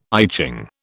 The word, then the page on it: I Ching: British English